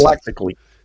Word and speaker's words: prophylactically: And antiviral can also be used, what is that word? How do you pronounce it? – Prophylactically. – Thank you.